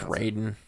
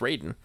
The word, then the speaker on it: Raiden: It's Raiden. – Depends on how you pronounce it. – Yeah, when you pronounce it properly, it's Raiden.